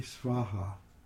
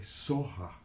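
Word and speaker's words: svāhā: And the mantra is Om Tari Tutari Turi Svaha. The Tibetans, instead of saying Svaha, which is a Sanskrit pronunciation, Tibetan's a little softer. They say Soha. – Mm. – They don't have that hard, sharp V pronunciation.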